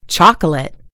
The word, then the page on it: chocolate: American English